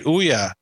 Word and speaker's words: Ouya: Ouya? – That's right, Ouya.